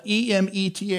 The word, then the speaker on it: emet: The word faithful in the Old Testament is, is, is a Hebrew word we would spell it E M E T H and pronounce it emet, which means literally faithful or truth.